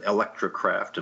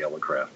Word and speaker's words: Elecraft: And, of course, we've been called Electrocraft and Electrocraft and so on. But I think by now, actually, most people sort of know it's Elecraft.